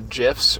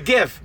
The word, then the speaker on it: GIF: It's not the peanut butter. There's not a J there. – Exactly. It's a GIF.